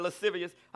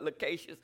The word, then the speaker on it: lascivious: We were talking about this at Bible study, lascivious. I used to pronounce it differently because I wasn't well learned. I used to call it lacacious.